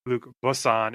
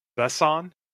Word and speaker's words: Besson: And it is, I believe, pronounced not Luc Besson. It's like Besson.